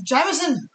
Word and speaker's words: Jameson: Jameson. – Jameson. – Jameson. – That's how you're supposed to pronounce it. That's how the Irish pronounce it. I have to. – Even then, it's Jameson.